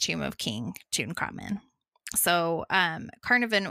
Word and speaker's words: Carnarvon: I kept calling him Carnarvon. It's not. It's Carnarvon.